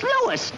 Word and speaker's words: Louis: Sounds like there might be a story at the plant, Lois. – Lois? Me name is Lewis, not Lois. Gee whiz, everybody interpolates me name wrong. It's Lewis. L-O-U-I-S.